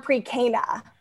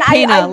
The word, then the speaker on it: Cana: What's the correct pronunciation, before I finish your intro, of the word pre-Cana? – No, you nailed it. – Okay. – Oh, okay. Great. And Cana, like almost like canine, like not Cana.